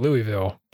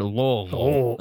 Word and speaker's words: Louisville: I call it Louisville. But I'm wrong. So Louisville. Yeah. – Louisville. – You got to swallow it. You got to swallow every syllable of that word. Louisville. That's like the only correct way of saying it.